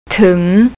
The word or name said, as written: tung